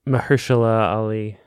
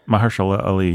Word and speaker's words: Mahershala Ali: I'm going to get his name wrong. It's not Mahershala Ali. – Mahershala Ali. Yes.